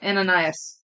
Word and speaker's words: Ananias: Ananias. Ananias. – Okay. – No, there's no sis. Ananias. – Ananias. – Yeah. – Okay. – Ananias.